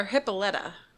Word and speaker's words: Hippolyta: Is it Hippolyta or Hippolyta? – Hippolyta, actually. But okay. Hippolyta is the correct pronunciation.